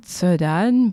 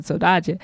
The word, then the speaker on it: Saudade: Hope y'all enjoyed Saudade by Cesária Évora. I realized that I pronounced it the Portuguese way, or actually, no, I pronounced it the Brazilian Portuguese way, and I said Saudade, but you win some, you lose some.